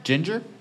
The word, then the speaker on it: Jinjer: Is that how you pronounce that name? – Ginger? – Yeah.